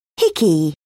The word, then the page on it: hickey: British English